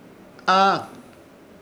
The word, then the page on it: Haa: Ishir Chamacoco